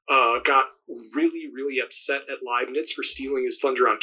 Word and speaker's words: Leibniz: Some people say Liebniz, but it's German, and in German it's really easy to figure out, because if you're pronouncing it the German way, and in the United States they berg the berg and all those things, but in German, the way that if you're doing pronouncing it the European way, the last letter is what you say.